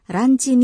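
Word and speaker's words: Ranchi (ランチ): Ranchi adalah pengucapan bahasa Jepang untuk kata lunch atau makan siang.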